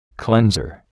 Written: cleanser

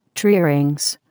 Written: tree rings

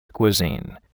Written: cuisine

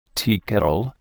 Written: teakettle

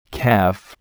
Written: calf

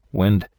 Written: wind

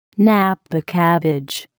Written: napa cabbage

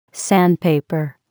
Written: sandpaper

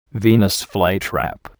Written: Venus flytrap

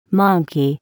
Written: monkey

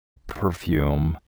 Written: perfume

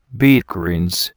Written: beet greens

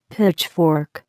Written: pitchfork

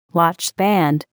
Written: watchband

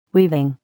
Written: weaving

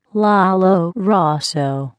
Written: lollo rosso

